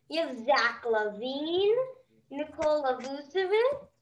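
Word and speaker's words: Vučević: But Vucevic, yeah. – Vucevic.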